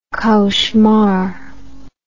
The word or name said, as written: cauchemar